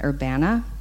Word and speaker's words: Urbana: I actually was corrected yesterday and told to pronounce it Urbana. I've never lived there. So apparently the locals call it Urbana.